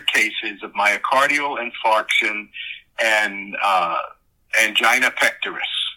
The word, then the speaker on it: angina: And the medical director on the set kept telling me, it's angina. And I said, well, I've never heard it pronounced angina. I've always heard it pronounced angina. No, it's angina, angina.